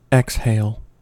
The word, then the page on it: exhale: American English